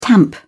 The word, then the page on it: tamp down: British English